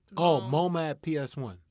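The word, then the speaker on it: MoMA PS1: Is it, like the MoM PS1? It's like, MoMA at PS1.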